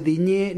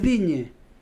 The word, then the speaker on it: Digne: no se dice Diñé ni Olicé. – ¿Ah, no? – No, porque no tiene acento en la E. Las E finales en francés, si no tiene acento, no se pronuncia. Es Digne.